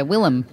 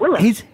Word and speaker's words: Willem: Did you drop a syllable out of the first name? Did you say Willem? Or is that how you say it? – Yeah, Willem. – That's how you say it, Willem.